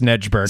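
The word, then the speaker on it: Snejbjerg: I think it's pronounced Snedberg.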